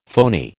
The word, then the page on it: phony: American English